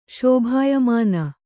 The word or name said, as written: ಶೋಭಾಯಮಾನ